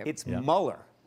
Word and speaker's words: Mueller: When you're biting on a cruller, just remember Robert Mueller, and that should ensure that you'll never get it wrong.